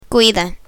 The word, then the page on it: cuida: spanish